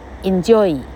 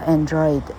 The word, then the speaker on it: Android: 而不是念作 Enjoy。啊，enjoy 哈，enjoy 哈，什么奇怪的 en enjoy at，不晓得到底怎么念了哈。好，它念作 Android，安卓手机 Android，意思是，呃，人的哈，哎，是机器人的还是人的哈？